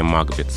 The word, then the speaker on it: Макбет: И вот именно в этом произведении по традиции закрепилось произношение «Макбет» с ударением на первом слоге.